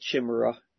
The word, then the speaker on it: chimera: It's spelled chimera, but it's chimera, okay, just in case you don't know. Spelt chimera or chimera or chimera, C-H is spelled, but it's chi, it's pronounced chi, and a lot of chimeras and hybrids, hybrid creations.